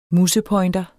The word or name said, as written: musepointer